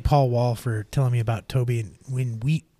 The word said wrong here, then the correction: Nwigwe: Nwigwe. – Toby Wigwe, I think is how you say it.